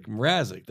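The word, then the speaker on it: Mrázek: Mrazek. – Is that how you say it? – Yeah.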